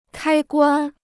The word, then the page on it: 开关: kāi guān